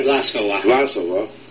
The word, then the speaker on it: Vlasova: Well, very interested recently in many of the Russian activities and relationships, and one especially has been the Vlasova, did I say that right? – Vlasova